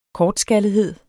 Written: kortskallethed

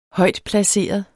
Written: højtplaceret

